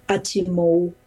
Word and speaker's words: atimo: And so datimon, kitachimon, atimo, with a kind of W sound at the end of it.